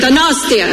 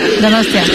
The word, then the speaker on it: Donostia: ¡Donostia! I'm trying. – Venga. – Es que recasco, gosh. Ok. I'm all right. Es que recasco, gosh. How do I say it? Donostia. – Donostia.